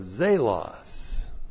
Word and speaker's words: zelos: We have the conjunction Allah, meaning even, and the accusative singular of zelos. Z, long E-L-O-S. Zelos.